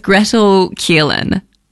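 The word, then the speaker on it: Gretel Killeen: First of all, it's Gretel Keelan, I believe. Am I saying that right? – Yeah.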